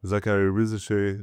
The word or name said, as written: Zaccharie Risacher